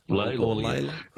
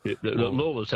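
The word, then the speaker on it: Lalor: Layla. Lawler's how it's supposed to be pronounced, but Layla's the other way.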